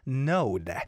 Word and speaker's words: NOHD: Today's song was Salty by Brostown, Chong Yong-hoon, Haeun, and N-O-H-D, but I think this guy's name is pronounced as Node.